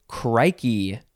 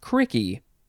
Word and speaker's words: crikey: Next is crikey. C-R-I-K-E-Y. You could also put a C in the middle. Crikey. You could also say, well, that second spelling would be pronounced cricky. Crikey and cricky.